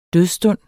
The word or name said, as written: dødsstund